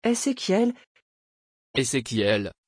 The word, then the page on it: ezékiel: French